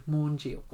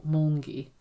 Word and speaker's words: Maungy: M-A-U-N-G-Y. Maunji. Or Maungi.